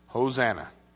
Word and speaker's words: Hosanna: How do you say that in Hebrew? Hosanna.